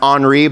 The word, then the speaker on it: Henri: His name is probably pronounced Henri, but I'm from Mississippi, so we're sticking with Henry.